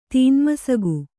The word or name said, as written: ತೀನ್ಮಸಗು